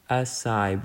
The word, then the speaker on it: acai: Acai, an acai bowl. Maybe it's acai. Let me double check. Acai bowl. acai pronunciation. Ah, okay, it is acai.